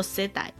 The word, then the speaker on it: 世代: 嗯、那首先要讲到世代这个字，世代这个字念作 seidai，就是英文的 generation。